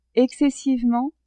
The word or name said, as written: excessivement